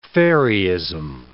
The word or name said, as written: fairyism